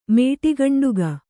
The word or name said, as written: ಮೇಟಿಗಂಡುಗ